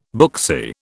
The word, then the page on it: booksy: British English